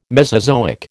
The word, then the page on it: Mesozoic: American English